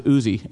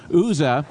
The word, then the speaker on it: Uzzah: We know that the ark is on a cart, and we know that cart hits a Philadelphia-sized pothole, and Uzzah, and I think the East Coast, you guys pronounce it Uzi, but Uzzah reaches out.